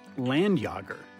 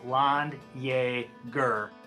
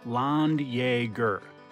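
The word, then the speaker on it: Landjäger: I thought it was pronounced Landjager. – But the proper pronunciation is Landjager. – Landjager.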